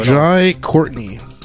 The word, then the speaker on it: Jai Courtney: Jai Courtney. – Who? – You said it right. Good. He pronounced, after 30 episodes, he pronounced a name right. – Jai Courtney.